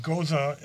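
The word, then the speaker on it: Gose: Yeah, it is a Goza is the correct pronunciation, but the label, if you look at it, we say it's How Goes It.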